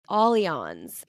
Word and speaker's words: Allianz: It because you're so it's spelled Allianz, like A-L-L. Yeah. But yeah. I like Googled it to have them respond like to have the it said to me, and it's Allianz, and it's I think it's French.